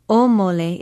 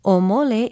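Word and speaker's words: Omole (ʻōmole): Omole is spelled with an okina, O-M-O-L-E, and there is a makron or kahako over the first vowel. Pronounce it omole.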